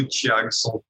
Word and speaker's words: Thiagson: Meu nome é canal do Thiagson, T-H-I-A-G, o G é mudo, G-S-O-N, canal do Thiagson no Facebook e no Instagram e no YouTube.